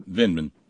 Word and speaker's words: Vindman: Vindman.